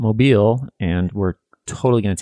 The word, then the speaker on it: Mobile: But it is not Mobile, Alabama. It's Mobile, Alabama.